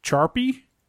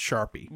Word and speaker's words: charpie: It seems like this is just pronounced Sharpie.